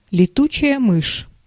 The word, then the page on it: летучая мышь: [leˈtʊʨaʲa mɨʂʲ]; /lʲɪˈtut͡ɕɪjə mɨʂ/; letutšaja myš